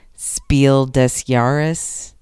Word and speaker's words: Spiel des Jahres: My husband told me I should just say Spiel des Jahres. – Yeah, that's how the board gamers say it. – But I speak a little bit of German, so I'm saying it properly.